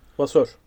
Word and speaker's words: Vasseur: Ee, Ferrari'nin yeni patronu nasıl okunuyor onun adı? Vosseur. Nasıl okunuyor? – Vasseur. Frederic Vasseur. Vasseur. Ha, tamam güzel.